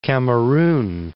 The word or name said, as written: Cameroon